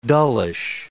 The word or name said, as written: dullish